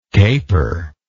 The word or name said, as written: caperingly